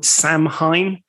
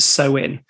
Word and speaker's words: Samhain: Yeah, and just for people to know, if you were to see it written down, this ancient Celtic festival looks like it's called Samhain, but just given it's a Celtic word, it's actually pronounced Samhain.